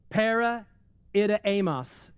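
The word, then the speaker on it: para-itimus (παρεπίδημος): The Greek word for sojourners is para-itimus is how you pronounce that.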